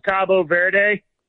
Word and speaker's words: Cabo Verde: They in Spanish, it's Cabo Verde. – Cabo Verde. – Cabo San Lucas or whatever in Mexico. Cabo Verde. But okay. The English pronunciation is Cape Verde.